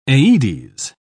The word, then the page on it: aedes: British English